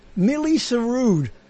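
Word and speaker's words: Milli Surood: And it's called Millie Sarood. That's how you say it.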